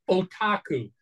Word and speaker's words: Otaku: Right, and that was what Kiss Otaku is that is that how you pronounce that? Uh, o- otaku. – otaku. Otaku. Sorry. Yeah.